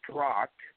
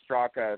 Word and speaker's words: Straka: So the last A is silent. It's actually pronounced Strock.